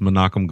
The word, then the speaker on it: Menachem: He said Menahem, but it's Menachem Golan.